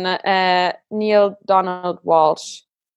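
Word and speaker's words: Neale Donald Walsch: Okay, also es gibt 2, aber eins ist für mich das absolute Lieblingsbuch ist Gespräche mit Gott von Neil Donald Walsh. Ich glaube, so spricht man ihn aus.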